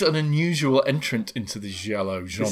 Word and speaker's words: giallo: I think this movie is counted as being part of a sort of film school, which I think is referred to as giallo. I think that's the Italian pronunciation of it. – Giallo.